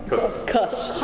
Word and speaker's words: Kuhs: You pronounced your last name Kuz. Is that right? – Kuz. – I said it wrong. – Somebody I know, same name, Kuz, they say. Is it Kuz? – K-U-H-S, yeah. – Okay. K-U-H-S, Kuz, yeah.